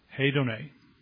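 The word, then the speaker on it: Hedone (ἡδονή): Hedone. That's not what it looks like, but that's how it's pronounced.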